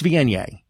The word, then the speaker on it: Viognier: most people can't pronounce it because it looks like the word should be pronounced Viognier. It's a V-I-O-G-N-E-R, but it's Viognier.